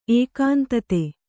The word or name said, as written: ಏಕಾಂತತೆ